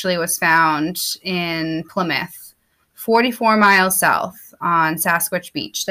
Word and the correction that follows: Saquish: Did I say that right? – Saquish.